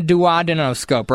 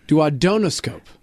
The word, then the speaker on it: duodenoscope: We're using a duodenoscope, right? – It's pronounced duodenoscope.